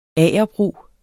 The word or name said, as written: agerbrug